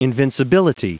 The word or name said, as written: invincibility